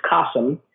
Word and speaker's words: Kasim: His name is Kasim. It rhymes with the word awesome, which is true.